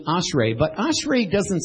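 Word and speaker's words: ashrei (אשרי): This word is pronounced ashrei. Everybody say ashrei.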